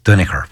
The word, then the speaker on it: Duinnachair: it was spelled D-U-I-N-N-A-C-H-A-I-R, which is Duniker, which is Danoher.